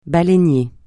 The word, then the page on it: baleinier: francais